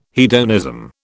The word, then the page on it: hedonism: British English